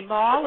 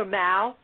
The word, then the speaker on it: Mal: You can say Mal or Mal. Most people say Mal, but that's okay, Parthenia, whatever you're comfortable with.